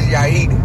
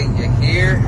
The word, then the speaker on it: Yair: My name is Yair, but white people always say Yair. Like they always say something like that.